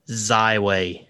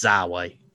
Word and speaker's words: Xyway: Except in Kentucky, it's Zyway. – It's Zyway. – Zyway. You kind of got to say it all together.